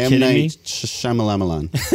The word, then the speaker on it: M. Night Shyamalan: Are you M. Night Shyamalan.